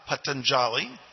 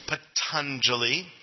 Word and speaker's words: Patanjali: So those are the eight limbs of yoga espoused by Patanjali, not Patanjali, Patanjali. I'm sure he was a jolly fellow, but we don't put that in the pronunciation of his name.